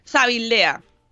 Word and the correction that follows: Zalvidea: Es que siempre me equivoco con Zabala. Zalvidea.